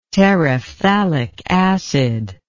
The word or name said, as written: terephthalic acid